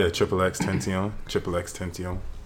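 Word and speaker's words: XXXTentacion: No, it's actually pronounced XXX Tension. – Tension, really? – Yeah, like he did an interview with like a radio station, and they asked him like, "How do you say your name?" He's like, "Oh, it's XXX Tension." So you have to say it with an accent. – With a French accent? – Tension.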